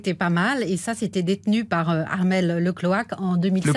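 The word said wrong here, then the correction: Le Cléac'h: Le Cléache, vous ne l'appelez pas le Cloaque.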